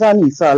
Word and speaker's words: Anissa: It is Anissa, like Lisa.